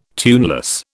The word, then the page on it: tuneless: British English